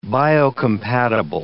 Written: biocompatible

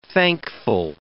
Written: thankful

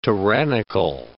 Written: tyrannical

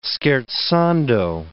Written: scherzando

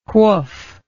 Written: whoof

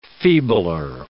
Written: feebler

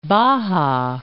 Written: Baja California

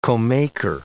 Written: comaker

